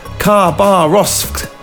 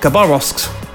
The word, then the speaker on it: Khabarovsk: the word starts with an X, for starters. It's pronounced Khabarovsk. Khabarovsk.